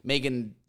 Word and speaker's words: Megan: Also, I hate the way that the Americans say Megan. It's M-E-G-A-N, Megan. They're like Megan. Not even Megan, like Megan. And I'm like, Megan's not her name. Megan is swapping the vowels in her name. Like M-A-G-E-N.